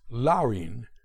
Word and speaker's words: Laurine: More than any other individual, it was Copeland's sister, Laurine, spelled L-A-U-R-I-N-E, but pronounced Laurine, 8 years his senior, who sparked his interest in music.